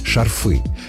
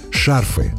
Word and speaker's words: шарфы: Шарфы. По аналогии с привычными формами. Зонты, мосты. Но литературная норма иная. Шарфы с ударением на корне. Слово «шарф» во всех формах сохраняет ударение на первом слоге.